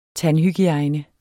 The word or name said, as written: tandhygiejne